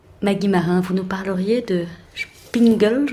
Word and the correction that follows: Singspiel: je le prononce pas mal. – Singspiel. – Singspiel. – Voilà.